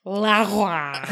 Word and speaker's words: Laoghaire: And this would be a good time to remind people who haven't read the books that Leary's name is spelled L-A-O-G-H-A-I-R-E. La Roi. Lady. Anyway, he goes down. It's like that scotch over there is Lafroy, but it's got like a G in there. Like, yeah.